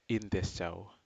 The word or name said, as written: intesjao